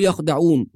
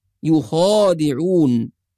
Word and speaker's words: يخادعون: فبعض الناس يقرأ الكلمة الأولى يخدعون ولا ينتبه إلى الألف المرسومة بعد الخاء، في ألف صغيرة مرسومة بعد الخاء، وهذه الألف ننطقها عند القراءة ولا نتركها، فلا نقول يخدعون وإنما نقول يخادعون.